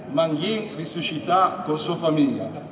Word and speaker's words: Manghi risuscita con sua famiglia: Io spero di pronunciare bene Manghi risuscita con sua famiglia. È giusto? – Bravo!